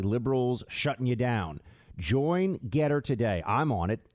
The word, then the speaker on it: Gettr: On Getter, pronounced G-E-T-T-R, or rather spelled G-E-T-T-R, pronounced Getter.